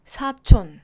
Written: 사촌

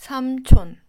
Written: 삼촌

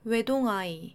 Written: 외동아이

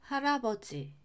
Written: 할아버지

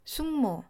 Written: 숙모

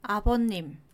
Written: 아버님

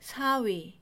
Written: 사위

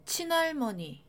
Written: 친할머니

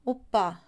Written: 오빠

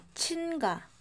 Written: 친가

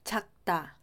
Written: 작다

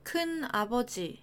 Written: 큰아버지

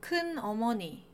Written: 큰어머니

